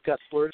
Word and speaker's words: Gutzler: Well, I've got a nice website, It's Steve Gutzler.